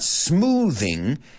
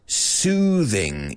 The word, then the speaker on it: soothing: Well, just, I guess the main note over here is it's not smoothing. It's soothing. Y cuidado con la pronunciación porque no es como la TH tradicional. O sea, es como más una right? Soothing. Que te vibra un poco la lengua.